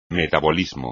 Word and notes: metabolismo: Spanish

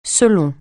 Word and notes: selon: francais

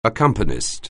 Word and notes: accompanist: anglais

